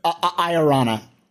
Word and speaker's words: Iorana: Wait, what's the correct pronunciation? Is it Rona? – Iorana. You're supposed to roll Iorana. The R if you can. Welsh names typically have three syllables with an emphasis on the or four. An emphasis on the middle one.